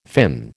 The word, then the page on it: fin: American English